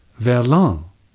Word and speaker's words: verlan: French has verlan. V-E-R-L-A-N.